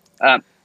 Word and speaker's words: Valladares: But they're made by, you know, people like, you know, Oscar, Leaf by Oscar is one, or Oscar Valladares, how do you pronounce it? – Valladares.